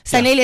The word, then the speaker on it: Sanelly: Sanelly is it, like Nelly from SA.